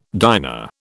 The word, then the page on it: diner: British English